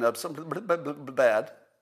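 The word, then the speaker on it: etesevimab: Let's see, monoclonal antibodies, bamlanivimab and etesimab. And I think I pronounced those correctly. Let me say them again. Bamlanivimab and etesimab.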